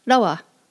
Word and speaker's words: rawa: In pre-contact times, it was pronounced rawa, spelled R-A-W-A, and you'll still see this pronunciation in some of the older dictionaries. The R is pronounced like the Spanish R in Ricardo or Rafael, or the Japanese word in ramen.